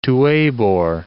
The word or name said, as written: Tuebor